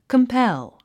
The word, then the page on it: compel: kəmpél; カンペル